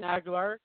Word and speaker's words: Agholor: You got the name right. It is Nelson Agholor. You pronounced it right, but you're right.